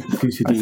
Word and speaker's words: Thucydides: I'm glad you pronounced that rather than I had to. – Yeah, I think it's Thucydides. – I think that's right.